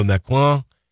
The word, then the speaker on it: Macron: He's meeting with the French President Emmanuel Macron. That is the correct way to pronounce it.